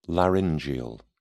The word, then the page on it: laryngeal: British English